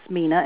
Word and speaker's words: Mena: My first name, which is Mina, and it's spelled M-E-N-A. – Mm-hmm. – At Art, A-R-T, F-X, signs, plural, .com.